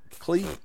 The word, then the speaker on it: Klei: How do you say their name? K-L-E-I? Klee? – Yes.